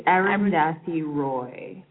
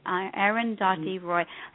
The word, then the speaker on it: Arundhati Roy: She's Arundhati Roy. – Arundhati Roy.